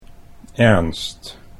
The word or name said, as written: Ernst